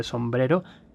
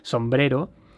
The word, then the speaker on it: Sombrero: Why it is called the Sombrero, the hat galaxy, or the Mexican hat galaxy. Sombrero, that is a nice pronunciation. Sombrero, not sombrero.